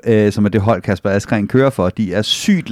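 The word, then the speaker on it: Kasper Asgreen: men jeg tror mere på Kasper Askren til Flandern eller Roubaix. Er det sådan, man udtaler ja, det? Ja.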